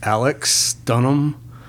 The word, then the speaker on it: Alex Dunham: It's not Alex, it's Alex Dunham.